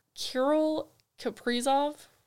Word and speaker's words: Kirill Kaprizov: For the Calder Memorial Trophy, you have, correct me if I say this name wrong, Kirill Kaprizov. – Yeah, you nailed it.